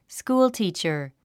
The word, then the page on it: schoolteacher: skúːltìːtʃə r; スクールティーチャァ